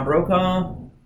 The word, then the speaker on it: Brokaw: So, you know, put us up there with Tom Brokaw. Is that how you say his name? – Yes.